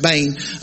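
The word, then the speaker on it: Bane (בן): Literally, the word is Ben, or pronounced Bane, literally means a son.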